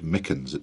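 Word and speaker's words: St. Michan's: in the Church of St. Mickens, it's pronounced.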